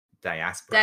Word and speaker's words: diaspora: Wait, you told me how to pronounce this word. – Diaspora. – Diaspora. See, I've only ever read it, so I never heard it pronounced.